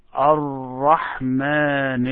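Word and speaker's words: Ar-Rahman (الرحمن): As if you have two Ra. It is not one Ra, but try to pronounce it and make it harder. Ar-Rahman. Ar-Rahman Ar-Rahim.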